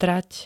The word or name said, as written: trať